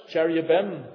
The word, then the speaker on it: cherubim: You read about cherubs, cherubim, we pronounce it, were put at the entrance to the gate to the garden to keep man out.